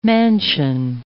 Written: mansion

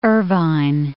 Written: Irvine

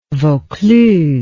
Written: Vaucluse

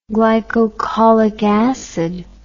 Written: glycocholic acid

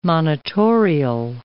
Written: monitorial

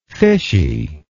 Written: fishiness

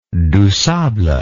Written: dusable

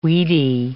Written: weedy

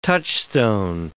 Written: touchstone

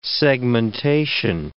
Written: segmentation

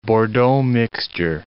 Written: bordeaux mixture

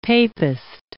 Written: papist

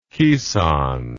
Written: kisan

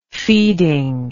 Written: feeding

